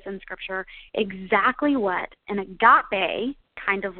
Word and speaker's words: agape: The word we are looking at in these verses in Galatians is the word agape, okay? Agape, and that is spelled A-G-A-P-E.